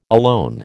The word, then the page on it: alone: American English